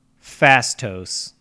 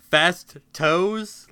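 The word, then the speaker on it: Phastos: He goes by the name Fastos. – Fast toes, like. – No, but goddamn, I wish there was a character called Fast Toes. It's spelled P H A S.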